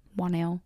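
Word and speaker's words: Whannell: I was going to say Wannell. – That sounds right. Wannell.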